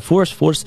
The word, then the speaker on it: fourth: А force, типу, пишеться F-O-U-R-T-H. Але, в принципі, читається force плюс-мінус так само.